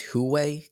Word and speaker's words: Huawei: There was a news article, you may have heard about the Chinese executive, I think it's Huawei.